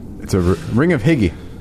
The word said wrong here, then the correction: Hygge: That's really, it's pronounced Hygge. – Hygge? – Hygge.